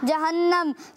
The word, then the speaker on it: جہنم: جہنم نہیں ہوتا پیارے بچوں، جہنم ہوتا ہے۔ اس کا صحیح تلفظ کیا ہے؟ – جہنم۔